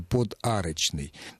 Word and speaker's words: подарочный: Я пытался произнести его не так, как произносится слово «подарочный», я пытался произнести его с маленькой паузы «подарочный». Но на письме эту паузу отразить не удастся.